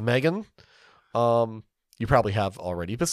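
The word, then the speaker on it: M3GAN: Do you still pronounce it Megan, or do you try to somehow pronounce that three? Is the No, E? It's Megan. – It's not Methagan? – No.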